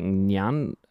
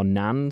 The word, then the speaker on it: Ngan: Liv, N-G-A-N is the surname. And he said it's pronounced like Nyan, or Nan.